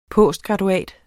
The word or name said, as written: postgraduat